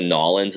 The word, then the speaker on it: New Orleans: I think it's definitely as close to Nollins as possible. So, like, so probably New Orleans. Where you put it all together.